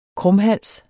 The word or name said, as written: krumhals